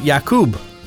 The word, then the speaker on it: Jakub: I thought it was either Jacob or Jakub, but Jakub seems to be the right way to say it.